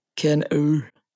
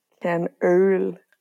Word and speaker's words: Kernöl: Aber wichtig ist ja noch, wir müssen die Aussprache noch kurz üben. Es heißt Kernöl. – Kernöl.